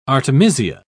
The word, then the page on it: artemisia: British English